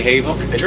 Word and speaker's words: Havoc: So I am outside the Worcester Palladium with the whole of, how do you pronounce the name of the band? Is it Havoc or a Havoc? – Havoc. – Havoc. – Yeah. – Does anyone ever say Havok? – Germans. – Yeah. – A lot of Europeans. – Europeans. Yeah. And then in South America, it's Havok.